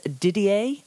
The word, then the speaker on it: Didier: Sure, it's Didier, and that's spelled D-I-D-I-E-R, Bedu, and that's V-E-D-U.